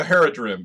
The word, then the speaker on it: Haradrim: The Haradrim, yeah. – Yeah, Haradrim. The thing about Lord of the Rings is you can see Tolkien, and there's the passage that's always quoted where he finds that Haradrim soldier lying, you know, in the field.